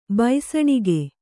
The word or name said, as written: ಭಸಣಿಗೆ